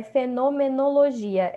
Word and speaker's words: fenomenologia: E a pronúncia é fenomenologia, é difícil mesmo.